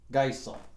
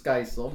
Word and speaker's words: Geisel: Oh, Geisel. – Wait. – What? – Wait, say it again. – we would say it Theodor Seuss Geisel, but this family would pronounce it Theodor Seuss Geisel.